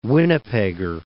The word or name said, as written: Winnipegger